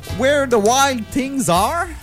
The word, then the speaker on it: Where the Wild Things Are: Sophie, tu viens nous parler du lancement, quand même assez récent, du film qui s'intitule, in English, Where the Wild Things Are. – Oui, tu le prononces très bien.